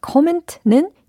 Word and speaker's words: comment: 자, comment는 영어로 comments. 이렇게 예, 코멘트가 아니라 comment. 이렇게 발음을 연습해 주시면 되겠어요.